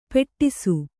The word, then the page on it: ಪೆಟ್ಟಿಸು: Kannada